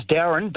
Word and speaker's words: Daron: So, yeah, it's Darren, but it's spelled D-A-R-O-N.